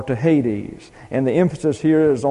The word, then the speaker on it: Hades: H-A-I-D-E-S. Now this is an eta here, so it's like a long E, and it gets an A sound, so this is Hades. Hades. And you have a iota subscript here, and you transliterate it, but it's not pronounced. So we wind up with Hades.